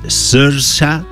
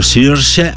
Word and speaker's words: Saoirse: Hay que pronunciarlo bien porque es gaélico. Es Saoirse o Saoirse Ronan.